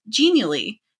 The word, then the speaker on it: Genially: One tool that I've found really helpful for that is a tool called Genially, and it's spelled G as in good, E-N-I-A-L-L-Y, Genially.